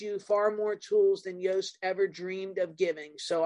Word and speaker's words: Yoast: So all the way along, there's been a plugin called Yoast to do, like, it rhymes with toast, but it starts with a Y, to do SEO and help you with SEO.